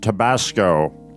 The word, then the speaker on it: Tabasco: But I think secondly, Chud, it's actually pronounced Tabasco.